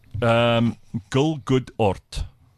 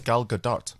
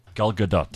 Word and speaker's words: Gal Gadot: He pronounced it as Gal Gadot. – Gal Gadot. That's right. That's what he said.